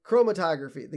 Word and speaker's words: chromatography: Chro- chromatography? – Chromatography, the gas chromatography.